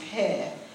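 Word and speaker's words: peh (פ): And the Hebrew letter for 80s is pronounced peh and the spelling is the 18th letter of the alphabet pet, which is also the Hebrew word for mouth.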